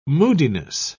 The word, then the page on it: moodiness: British English